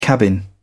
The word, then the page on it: cabin: British English